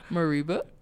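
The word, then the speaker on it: Mereba: So how would you say it? M-E, Reba. – My Reba. – That's what I said. Mariba.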